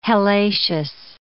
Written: hellacious

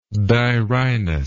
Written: dirhinous

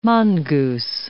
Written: mongoose

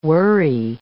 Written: whirry

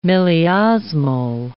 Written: milliosmol

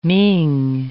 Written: Ming

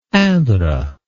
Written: indre

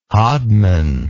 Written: hodman

